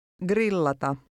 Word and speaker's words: grillata: The Finnish G by itself is pronounced like the G in the English word get. – Grillata.